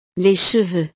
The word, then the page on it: les cheveux: frances